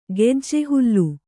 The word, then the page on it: ಗೆಜ್ಜೆಹುಲ್ಲು: gejje hullu